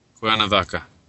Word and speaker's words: Cuernavaca: The Mexican Warrior was revealed as Juan Roman from Cuernavaca, Mexico, is it? – Oh, uh, Cuernavaca. – Cuernavaca. I did get it right.